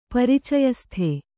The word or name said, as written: ಪರಿಚಯಸ್ಥ